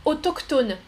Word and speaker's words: autochtone: Ah, Silvio, autochtone. En français, là, le CH, le CH, on prononce que. Autochtone.